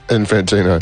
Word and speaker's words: Infantino: Infantino.